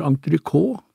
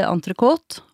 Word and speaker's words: entrecôte: Hvis vi beveger oss over på middagstallerkenen, for eksempel, en elegant fransk kjøttrett som mange uttaler entrecôte, er det riktig? – Nei, her sier vi entrecôte, og vi spiser det jo helst med pommes frites.